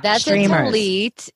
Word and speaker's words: tallit: Tallit.